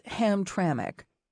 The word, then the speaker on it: Hamtramck: You pronounced it Hamtramck, as if that missing I were indeed there.